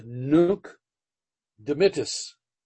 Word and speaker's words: nunc dimittis: Now in Latin, a little Latin lesson, it's nunc dimittis. Nunc dimittis, N-U-N-C, which means now. Dimittis means dismiss. Sort of sounds like it, doesn't it? Dimittis, dismiss. D-I-M-I-T-T-I-S. Nunc dimittis.